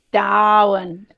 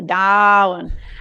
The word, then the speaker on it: Darwin: Darwin. Yes. You've got to pronounce it correctly. Darwin.